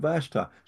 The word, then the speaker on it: Vashti: I said Vashti. She said, "You're the only ones that ever pronounced it right."